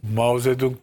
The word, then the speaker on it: Mao Zedong: Hasbelkader Mao Zedong döneminde, bak onu en iyi telaffuz edenlerden biriyimdir Türkiye'de. Herkes Çetong Meçong'dan. Çinlilerden duymuştum. Çünkü Mao Zedong beyefendinin adı.